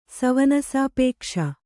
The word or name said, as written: ಸವನಸಾಪೇಕ್ಷ